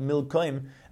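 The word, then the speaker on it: Milkom: The same letters, but it's pronounced Milkoim, and it was called that because of the Melucha, as the Pasuk says in Melucha Aleph.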